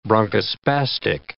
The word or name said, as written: bronchospastic